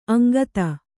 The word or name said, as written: ಅಂಗತ